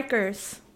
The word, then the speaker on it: Rikers: But like the level of horrible that it is that he went to like Rikers. – Yeah. – Am I saying it right? How do you say it? – Yeah, Rikers. I say Rikers, but I probably have a New York accent.